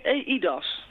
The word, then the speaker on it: eIDAS: En het heet ELDAS, zeg ik het goed? – Nou, het heet EIDAS. – EIDAS, oh, dat is EIDAS, ja.